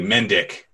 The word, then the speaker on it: Mendick: you say it phonetically, Mendick.